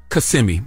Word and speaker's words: Kissimmee: Donkey of the day for Monday, April 24th goes to a man in Kissimmee, Florida. His name Kissimmee. – Is Kissimmee. You right.